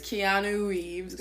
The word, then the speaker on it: Keanu Reeves: It's Keanu Reeves, guys.